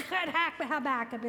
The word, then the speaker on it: Habakkuk: So I said, first of all, I've had a cat that had problems with fur balls, and so when he'd cough it up, Habakkuk is what it sounded like to me.